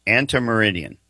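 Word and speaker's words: ante meridiem: A-N-T-E. How do you say that? Antemeridian.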